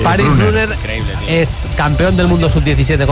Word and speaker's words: Brunner: Paris Brunner, con dos N's, Brunner alemán. – Ahí no tiene problema el Sala en pronunciar, Bruno. – Brunner.